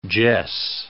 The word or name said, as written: jess